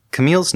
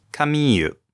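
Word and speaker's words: Kamille: In the Japanese, Camille's name is pronounced Kamiyu. In the English dub, it's pronounced Camille. But I'm not going to take pronunciation advice from dubbed anime.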